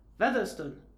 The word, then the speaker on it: Featherstone: You're saying it wrong, it's Featherstone.